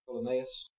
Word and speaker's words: Ptolemaeus: I think you pronounce that Ptolemaeus, almost like it starts with a P. Just forget about the P, and the accent is on the A, Ptolemaeus.